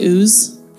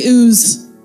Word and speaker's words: Uz: There was once a man named Job who lived in the land of, how do you say that? I like to say Uz, personally. It's just a personal preference. Say Uz, the land of Uz.